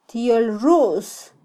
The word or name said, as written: tyolrus